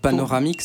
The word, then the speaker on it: Panoramix: Paronamix ? – Ouais, c'est... Oh, il y a une petite inversion de syllabe, mais peut-être que va venir à... – Panoramix ? – C'est ça, c'était ça, voilà.